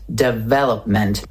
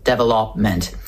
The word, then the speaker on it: development: They say development with the stress on the third syllable, development. But that's wrong. The stress falls on the second syllable, deve, development, development.